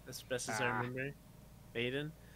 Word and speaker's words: Veden: So my guess as to spell it was V-E-Y-D-A-N. – Okay. It is V-E-D-E-N, which is why Elliot went for Vaden. – Okay. – But it's pronounced Vaden. – Okay. – Vaden.